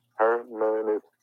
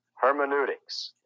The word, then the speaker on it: hermeneutics: But hermeneutics, and let me just take a minute to spell it for you H E R M E N E U, hermeneutics, hermeneutics.